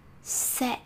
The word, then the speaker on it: sẽ: The letter S is meant to represent the sh sound, like the sh in English. Hanoi has lost this sound they pronounced this letter as a s. So in Hanoi, this word is pronounced as sẽ.